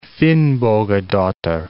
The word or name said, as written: Finnbogadottir